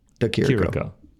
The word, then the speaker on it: De Chirico: I believe it is just a Chirico. De Chirico. – Yeah. Alessio De Chirico. There it is. Saying it properly.